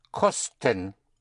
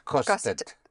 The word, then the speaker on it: kostet: How would you say it costs? Es kost. – Es kost. – Tet. Es kostet. – Es kosted. – Es kostet. – Es kostet. – There's a T, yes. Es kostet.